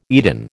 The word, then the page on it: Eden: American English